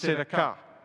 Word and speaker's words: tzedakah: In the Old Testament, it's pronounced tzedakah. Tzedakah.